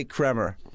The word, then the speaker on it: Kremer: But it's kind of spelled K-R-E-M-E-R. – Yeah, it is spelled. – For showbiz, it's confusing when you got a name that people not sure how to say it. – Is there another Howard Kramer on IMDb in the unions that you could not switch your name to Kramer? – I wouldn't switch the spelling. I'd rather switch the pronunciation.